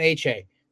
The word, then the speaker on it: Achane: Uh, so it's pronounced De'Von Achane. Devin A Chain.